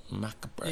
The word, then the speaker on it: macabre: I know, but it's spelled out macabre. – Yeah, but it's pronounced macabre.